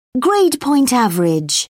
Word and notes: grade point average: British English

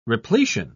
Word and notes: repletion: British English

